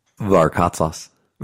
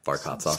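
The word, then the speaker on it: Varkatzas: Varkatsas. – Varkatsas. – Yeah. – Really? – Yeah, Varkatsas. – Spell it. – V-A-R-K-A-T, Z as in zoo, A S as in Sam.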